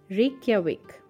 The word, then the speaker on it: Reykjavik: And the capital, oh my god, people have so much problem remembering its pronunciation. So it is Reykjavik, famous for its rugged landscapes and scenery.